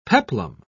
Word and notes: peplum: British English

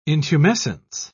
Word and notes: intumescence: British English

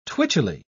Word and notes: twitchily: British English

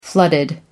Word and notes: flooded: American English

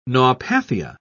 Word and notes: naupathia: British English

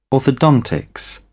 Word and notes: orthodontics: British English

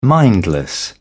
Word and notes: mindless: British English

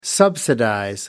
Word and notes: subsidize: American English

